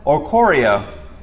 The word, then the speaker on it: Oricorio: I think this is Oricoro? Oricoro? Oricorio. That sounds fancier.